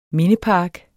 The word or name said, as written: mindepark